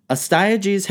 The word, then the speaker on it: Astyages: Now, I want to remind everyone, before I get too far into this, we don't really know exactly how these names would be pronounced. So for the sake of our story, it's Mandane and Astyages. Got it? Cool, let's move on. Astyages has a dream that his daughter takes a piss so bountiful that it floods all of Asia.